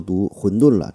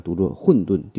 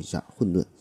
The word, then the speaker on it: 混沌: 上期节目中我一直一直都读混沌了，这不对哈，应该读作混沌。第四声特此更正一下，混沌。